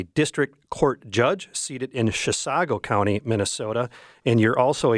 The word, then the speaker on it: Chisago: Well, thanks for having me, and thank you for pronouncing Chisago correctly.